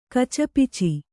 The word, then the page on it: ಕಚಪಿಚಿ: kacapici